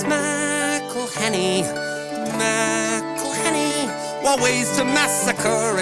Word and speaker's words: McElhenney: If there was doubt, now there won't be any. It's McElhenney.